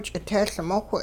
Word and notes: ch'itehLmowh: hupa

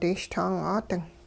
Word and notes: dishtang'aading: hupa

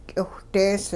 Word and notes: k'iwhdees: hupa

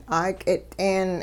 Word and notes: 'aak'it'een: hupa

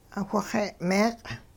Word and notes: whixe'-meeq': Hupa

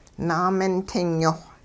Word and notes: naamintingyoh: hupa

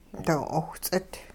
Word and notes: doo-'owhts'it: hupa

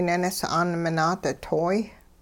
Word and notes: ninis'aan minaaditoy: Hupa

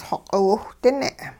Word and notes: xoq'iwhdine: hupa

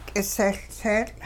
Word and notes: k'isehLtseetL': hupa

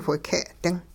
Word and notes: whike'-ding: hupa

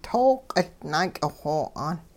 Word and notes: tooq naak'ixo'aan: Hupa

